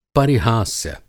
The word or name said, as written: परिहास्य